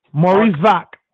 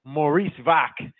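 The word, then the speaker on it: Mariusz Wach: What's his last name? Wachsi? – Maurice Vaak. – Maurice Vaak.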